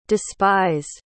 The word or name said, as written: despised